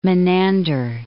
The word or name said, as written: Menander